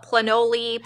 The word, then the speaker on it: Planoly: I don't even know if I'm saying – I've heard some people call it Planoly. – Planoly. How do you spell it? – It's P-L-A-N-O-L-Y.